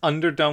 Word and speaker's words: Underdome: So this references, it's Underdome because it's unset for an unset, but also it rhymes with Thunderdome.